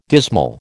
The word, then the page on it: dismal: American English